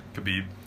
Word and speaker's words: Khabib: Khabib, yeah.